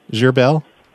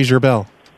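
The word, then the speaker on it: Gherbil: Gerbel.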